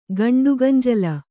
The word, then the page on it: ಗಂಡುಗಂಜಲ: gaṇḍuganjala